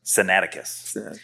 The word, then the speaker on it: Sinaiticus: So going back to the earliest manuscripts that we have of the Gospel of Mark, manuscripts like Codex Sinaiticus, how to pronounce this is a little tough, but it's Sinai Atticus. – Sinaiticus.